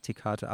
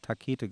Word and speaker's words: Tekate: Es ist wie Tekate. Ich sage aber auch immer Tekate falsch ausgesprochen. So heißt ja auch hier eins heißt der größten Biere. – Ja, es heißt Tekate, aber vorher habe ich immer auch Takete gesagt.